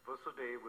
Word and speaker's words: Vasudeva: Yes, Vasudeva with a short A.